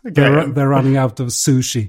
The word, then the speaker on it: sushi: sen efter den här resan var ju att jag att jag, gick in på Google Translate för att höra hur det egentligen uttalas på japanska. Okej. Okay. Och då uttalas det sushi. – Det är inget sje ljud eller tjej-ljud.